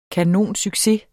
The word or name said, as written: kanonsucces